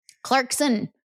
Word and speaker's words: Clarkson: I say her name wrong every time. Clarkson. There's no T.